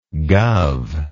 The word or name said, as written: guv